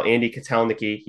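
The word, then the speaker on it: Andy Kotelnicki: and how Andy Katelniki, yes, that's how you pronounce his name,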